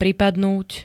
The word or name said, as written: pripadnúť